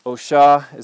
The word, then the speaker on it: Oshá: So Oshá, most commonly people say Oshá. Oshá is how that is traditionally pronounced.